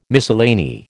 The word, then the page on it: miscellany: American English